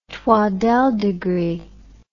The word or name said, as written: twaddell degree